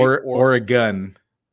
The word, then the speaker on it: Oregon: How am I supposed to say Oregon? – Oregon. – Oregon. – Yeah, Oregon. Oregon. Like organs in my body. – Yeah, Oregon.